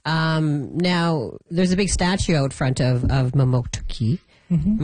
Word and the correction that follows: Mamoktokuti: Am I saying that right? Mamoktuki? – No, Mamoktokuti.